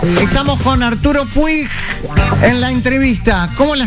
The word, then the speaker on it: Puig: Estamos con Arturo Puig. – Puig. – En Malos Pensamientos. – Acá en Uruguay me dicen Puch. – Puch. – Claro, también. – Porque así se pronuncia en Barcelona.